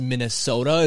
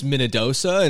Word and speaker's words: Minnedosa: It's not pronounced Minnesota. It's Minnedosa.